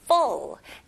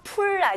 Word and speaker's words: full: The garbage can is full. 네, 가득 찬 거는 full. F 발음 명확히 해주시면서 풀 아닙니다. Full. The garbage can is full.